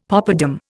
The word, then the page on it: poppadam: Indian